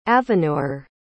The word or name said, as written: aveer